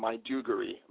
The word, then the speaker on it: Maiduguri: So what is the righteous way to say the sequel's title? – Okay, the sequel is called The Girl from Maiduguri. Maiduguri is a city in the northeastern part of Nigeria.